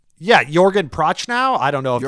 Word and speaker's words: Jürgen Prochnow: Sounded very good. It sounded like something you had like practiced and looked up. I was like, oh, that's a good pronunciation. – I think it's Jürgen Prochnow.